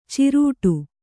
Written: ಚಿರಡಿ

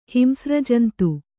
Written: ಹಿಂಸ್ರಜಂತು

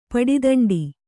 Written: ಪಡಿದಂಡಿ

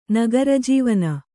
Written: ನಗರಜೀವನ

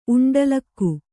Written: ಉಂಡಲಿಗೆ

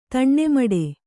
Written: ತಣ್ಣೆಮಡೆ

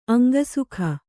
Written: ಅಂಗಸುಖ

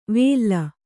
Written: ವೇಲ್ಲ

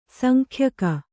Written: ಸಂಖ್ಯಕ

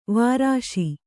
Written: ವರ್ಷ

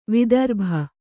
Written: ವೈದರ್ಭಿ

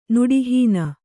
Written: ನುಡಿಹೀನ

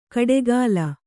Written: ಕಡೆಗಾಲ್

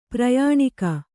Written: ಪರ್ಯಾಣಕ

